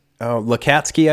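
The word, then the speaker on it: Lacatski: Do you know how that name's pronounced? – Yes. Lukatsky, I believe. – Lukatsky, that's right.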